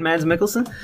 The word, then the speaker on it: Mads Mikkelsen: Am I saying that right, Mads Mikkelsen? – Yeah, I'm pretty sure that's how it's pronounced.